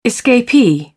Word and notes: escapee: British English

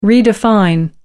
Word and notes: redefine: American English

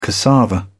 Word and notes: cassava: British English

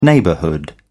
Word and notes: neighbourhood: British English

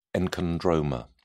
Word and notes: enchondroma: British English

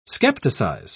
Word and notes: skepticize: British English

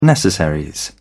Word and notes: necessaries: British English

